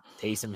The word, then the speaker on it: Taysom: I guess Tyson Hill. I don't know Taysom Hill, whatever you say his name is.